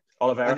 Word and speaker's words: Oliveira: I think Chandler, to fight Charles Oliveira, or have I said that right? – Oliveira.